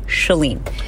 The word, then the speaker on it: Chalene: The name is pronounced Shah, as in shut the door, as in S H U H, Chalene.